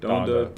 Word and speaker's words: Donda: Donda.